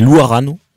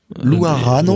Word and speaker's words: Louarano: Alors là, on le prononce en malgache, puisque ce sont les malgaches. – Alors vas-y, tu sais faire aussi... – C'est Louarano. – Louarano. – Voilà, et ça s'écrit Louarano.